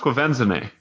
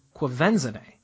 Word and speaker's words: Quvenzhané: Her name is pronounced Quvenzhané. – Quvenzhané, That is an excellent name.